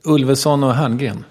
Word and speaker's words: Ulveson och Herngren: Before that, I'm doing a very interesting TV project based on the Swedish show, which was called Ulrich and Hertzberg Show or something. I don't know those guys' names even. I can never pronounce them. – Ulveson och Herngren.